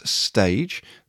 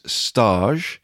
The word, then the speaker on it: stage: I have pronounced this role as stage. The actual pronunciation of this is stage. It is French for internship.